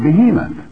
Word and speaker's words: behemoth: Incidentally, do you know how you pronounce behemoth in the Hebrew? You pronounce it behemoth.